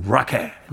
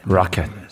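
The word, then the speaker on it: Rocket: 라켓 발음이 굴러갑니다. 라고 하신 분들 계셨는데, 네. 보세요. 보컬도 그렇게 발음하잖아요. 라켓. 라켓. 순수 영국 음.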